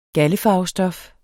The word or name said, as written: galde